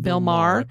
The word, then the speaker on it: Maher: Bill Maher.